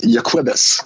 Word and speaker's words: Jacobus: And well, it looks like Jacobus Erasmus, but his first name is actually pronounced Jacobus, is I think how they say it.